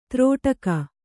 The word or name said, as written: ತ್ರಾಟಕ